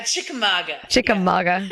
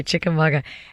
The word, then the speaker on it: Chickamauga: and also in Chickamauga, Georgia. Did I say that right? – Chickamauga. – Yeah. Chickamauga. – Yeah. It's a Cherokee word. – Okay, Chickamauga.